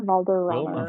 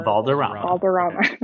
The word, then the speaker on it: Valderrama: Wilmer Valderrama. – Valderrama.